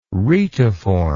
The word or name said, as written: retiform